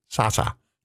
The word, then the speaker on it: Salsa: And this Saturday is Sasa. You got to say it like Jerry Seinfeld, Sasa, the Salsa Showdown.